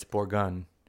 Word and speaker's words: Bourgogne: Well, it's Drouin, and then it's Bourgogne. But it's okay. Here's the deal. – Bourgogne? – Yeah. Here's the deal. – Like G-U-N? – Closer to that than gone or yeah.